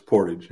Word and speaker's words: Portage: Did I say that right? Is it Portage? – It's Portage, yep.